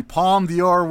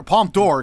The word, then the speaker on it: Palme d'Or: The Palme d'Or winner, Palme d'Or, excuse me, winner. I, uh, it's pronunciation. Thank you to our listener for correcting me.